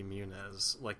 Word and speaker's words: Muniz: Is that how you pronounce his name? – Yeah. Frankie Muniz? Yeah.